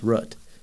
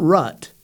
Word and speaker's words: root: Like, for example, that chess piece, the rook, that's how I pronounce root. It's rook, it's root. That's how I say it. It's not rut. That's different. Root, not rut.